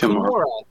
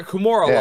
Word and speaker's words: Kimura: But Hannah Kimura? It's Kimura. – Yeah, it's Kimura. – Like a Kimura lock.